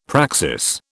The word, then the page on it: praxis: British English